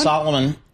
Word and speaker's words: Salomon: Salomon, those are the ski boots I wear, Salomon.